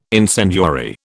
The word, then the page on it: incendiary: British English